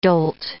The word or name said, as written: dolt